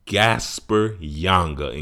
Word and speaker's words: Gaspar Yanga: And this song is called Gasper Yanga. Am I saying that right? Yep. Gasper Yanga.